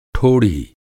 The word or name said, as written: ठोढ़ी